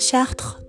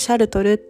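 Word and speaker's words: Chartres: その後に、えっと、すっごいこれおすすめなんですけど、パリの、パリから電車で 1 時間半弱ぐらいで行けるシャート、日本語の発音でシャルトルっていう、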